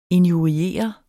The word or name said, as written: injurier